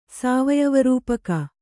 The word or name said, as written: ಸಾವಯವರೂಪಕ